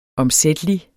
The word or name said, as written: omsættelig